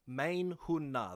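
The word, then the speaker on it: Main Hun Na (मैं हूँ ना): The first word is main, like the word primary, M-A-I-N. – Mm. – The second word is spelt the same as hoon, H-O-O-N. And the last word is just N-A.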